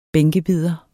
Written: bænkebider

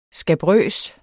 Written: skabrøs

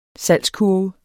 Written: salgskurve

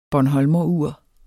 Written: bornholmerur

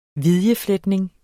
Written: vidjefletning

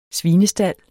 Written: svinestald

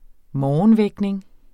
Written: morgenvækning

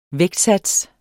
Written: vægtsats

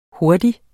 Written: Hurtigst

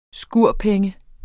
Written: skurpenge

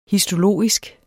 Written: histologisk

